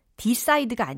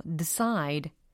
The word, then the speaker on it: decide: decide가 아니에요. decide. 거의 드처럼 들려요.